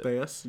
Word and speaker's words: Theis: Is that how you say it? Theis? I always mispronounce it. – Theis, yeah.